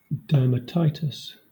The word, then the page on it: dermatitis: /ˌdɜː(ɹ)m.əˈtaɪ.tɪs/; Southern England